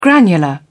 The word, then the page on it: granular: British English